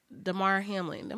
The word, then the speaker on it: Damar Hamlin: Damar Hamlin.